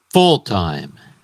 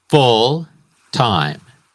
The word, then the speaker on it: full-time: Full-time. El énfasis o word stress está en la primera sílaba. Full-time.